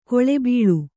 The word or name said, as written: ಕುಳಿಬೀಳು